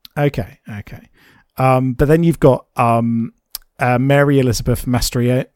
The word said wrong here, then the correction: Mastrantonio: Mastrantonio, I think it's pronounced as Maid Marian.